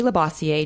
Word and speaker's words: Labossiere: It's Jody Labossiere, J-O-D-I, and then L-A, B like boy, O-S-S like Sam, I-E-R-E.